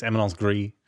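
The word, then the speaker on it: éminence grise: It's Eminence Gris.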